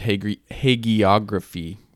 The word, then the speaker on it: hagiography: So this is kind of getting back to what you mentioned, hagiography, right? Did I say that right? – Yeah.